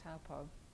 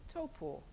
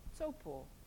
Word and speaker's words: Taupo: It took me a long time to stop saying Taupo. We go there quite a lot, and I still have to make myself say Taupo, Taupo.